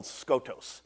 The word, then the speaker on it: skotos: Darkness in verse 8 is a really cool Greek word called skotos. S-K-O-T-O-S. Skotos. Real easy to pronounce.